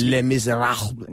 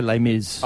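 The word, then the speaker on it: Les Misérables: Les Miserables. Now, this oh, takes. How do we say that? – Le, well, like, let's, Les Miserables. Yeah, well, you can say Les Mis.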